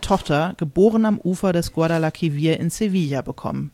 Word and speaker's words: Guadalquivir: Ich glaube, ich habe Guadalquivir gesagt, es ist aber der Guadalquivir. Ich hoffe, ich habe es richtig ausgesprochen.